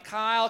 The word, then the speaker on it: Kyle: And when I was in elementary school, some kids couldn't say Kyle, so they called me Cow, C-O-W, Moo, Cow. Some kids like to make fun of my name, Kyle, because it rhymes with pile of you know what.